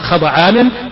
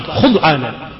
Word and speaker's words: خضعانا: والملائكة جمع ملك، وأصله مألك من الألوكة وهي الرسالة، وقد عرفنا الملائكة قبل قليل بأجنحتها خضعانا، هكذا تنطق خضعانا بفتحتين، فتح الخاء والضاد، ويصح بضم الخاء وتسكين الضاد خضعانا،